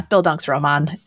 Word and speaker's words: Bildungsroman: I, I, I might be mispronouncing it, but I think I know the word. It's like Bildungsroman. – Yeah, Bildungsroman is is correct.